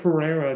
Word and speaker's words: Pereira: 6,800 and yeah, Joaquin Neiman's maybe the top Chile golfer from Chile, but Mito Pereira at 6,700 and again playing well this week.